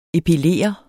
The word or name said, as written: epilere